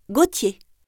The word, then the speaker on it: Gauthier: Gauthier. – Ça s'écrit comment? – G-A-U-T-H-I-E-R.